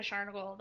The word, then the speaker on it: Scharnagle: So my Twitter is at Jessica Scharnagle.